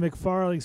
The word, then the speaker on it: McFarling: Alongside Mike Nizolik, the Virginia Tech football beat writer for the Roanoke Times, this is Aaron McFarling, sports columnist for the Roanoke Times.